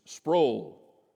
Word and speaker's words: Sproul: It's Sproul, rhymes with soul.